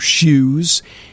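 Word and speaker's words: shews: Now, what are shoes? S-H-E-W-S. I looked this up. It is an archaic spelling and pronunciation of show or shows.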